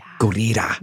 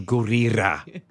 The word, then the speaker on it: Gurira: Gurira. – Mm-hmm. – She taught me how to say it. She was like, double roll the R. Do Gurira.